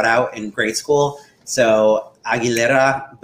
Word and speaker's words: Aguilera: And we're really excited today because we'll be reviewing Christina Aguilera's Bionic. – Aguilera, that's a good pronunciation.